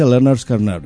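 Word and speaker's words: Lynyrd Skynyrd: Se pronuncia Leonard Skinner.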